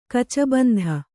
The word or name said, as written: ಕಚಬಂಧ